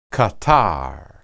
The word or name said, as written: qatar